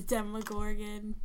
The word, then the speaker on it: Demogorgon: But, I just think that she's ruining Nancy's fun, and then she goes and gets eaten by the, by the Demogorgon. Did I say it right that time? Mm-hmm. – Yeah, that's how you say it. – I kind of want to call it the Dermagorgon.